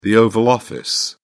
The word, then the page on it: Oval Office: British English